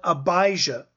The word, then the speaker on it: Abijah: Verse 5, in the days of Herod, king of Judea, there was a priest named Zacharias of the division of, I'm going to pronounce it the way it would be pronounced in Hebrew, Avia, but in English we would call it Abijah.